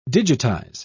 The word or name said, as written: digitize